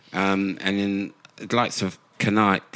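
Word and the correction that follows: Kanté: I pronounce his name. I want him back in that central position. – Kante. – Forgive me, Kante.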